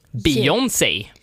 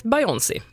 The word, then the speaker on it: Beyoncé: Där är uttalshjälpen B-I-J-O-N-N-S-E-J. Beyoncé. – P4 Halland. – Sveriges Radio. – Och det är tur för mig, för ibland har jag sagt Beyoncé. Och det är helt fel.